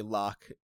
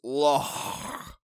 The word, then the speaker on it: Loch: Yeah, this Scottish guy is saying it pisses him off that people say Loch. It's Loch.